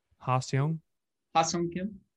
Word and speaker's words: Ha-Seong Kim: Ha-Seong Kim, they got from Korea. I don't know if I pronounced that right. I think I did. Ha-Seong Kim. – Yeah, Ha-Seong Kim. That was pretty good.